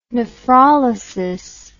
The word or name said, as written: nephrolysis